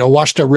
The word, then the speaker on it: Ouachita: The uh, what, the, what, how do you pronounce it? Which uh, uh oh, oh, oh. – Ouachita. That's right, Owashta River, Arkansas.